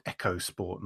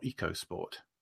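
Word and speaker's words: EcoSport: And the other one that really surprised me was the Ford, I believe it's pronounced Echo Sport, not Eco Sport.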